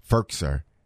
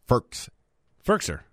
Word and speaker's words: Firkser: No, they're in three wides because I'm looking at Firkser, and that's how I always mess up his name. Firkser. – Anthony Firkser. – Yeah, I said it right the first time.